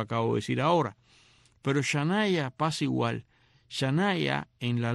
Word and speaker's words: Shania: Esto se escribe Shania, o sea, con SH, S-H-A-N-I-A. Yo no dudo que seguramente alguien escucha hoy ahora eso y le pondrá a la niña mañana, así. Se pronuncia Shania.